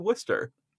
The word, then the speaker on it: Worcester: I know that doesn't make sense when people look at the actual name of the thing, as it looks like it is pronounced Worcester. That mm-hmm. is what that looks like. No. This was British people. It's weird. It's Worcester. That's just how that is.